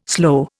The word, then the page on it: sloe: Indian